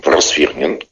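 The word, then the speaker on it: Просвирнин: Егор, извините, научите меня, пожалуйста, правильно, с правильным ударением произносить вашу фамилию. – Просвернин. – Почему мне казалось, что это аристократическая фамилия Просвернин?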